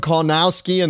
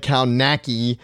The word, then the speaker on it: Kownacki: If you follow Adam Kownacki, and that is how you say it, even though it looks like Kownacki,